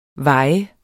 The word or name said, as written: vaje